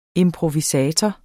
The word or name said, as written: improvisator